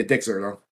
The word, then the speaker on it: Dexerto: And this is from Dexerto. Is that how it's pronounced? – Yeah, Dexerto.